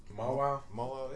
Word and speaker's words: Mawile: Mawile. Is that how you say it? – Mawile, yeah.